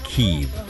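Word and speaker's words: Kyiv: it's now pronounced Kiev.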